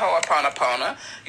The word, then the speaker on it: Ho'oponopono: And for those who haven't heard of Ho'oponopono, you spell it H-O-O-P-O-N-O-P-O-N-O.